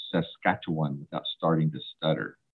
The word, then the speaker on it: Saskatchewan: and see hear about their work in Saskatchewan. Did I say that right? – Yes.